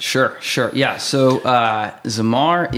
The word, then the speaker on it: Zamar: Zamar.